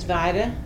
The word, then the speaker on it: Vida: My name is, is Vida Prince, and everybody calls me sister.